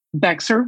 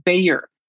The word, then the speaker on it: Bexar: Bexar County, Texas is pronounced Bayer, like the aspirin.